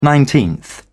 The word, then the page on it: nineteenth: British English